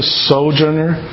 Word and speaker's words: sojourner: Being in the kingdom means that by definition I'm a sojourner. – Okay. Being in the kingdom that we, by definition, we are a, how do you say that word correctly? Sojourner? – Sojourner.